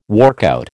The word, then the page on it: workout: American English